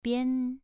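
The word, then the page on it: bian: tone 2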